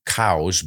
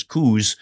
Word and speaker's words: Coues: And he pronounced his name Cows, but everybody looks at the spelling C-O-U-E-S and says Coos.